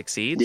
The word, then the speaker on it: Xyz: It's the black cards, XYZ. They're pronounced Xyz.